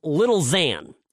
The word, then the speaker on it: Lil Xan: So there was some rapper in LA that I had never heard of, and his name I found out is Lil Xan, is how you pronounce it.